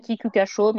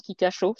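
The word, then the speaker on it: Kikuka Shō: alors, ça s'écrit Kikukacho, mais ça se prononce Kikacho, c'est le Saint-Léger japonais 46 000 personnes pour assister à la course à, Kyoto.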